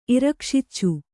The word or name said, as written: ಇರಕ್ಷಿಚ್ಚು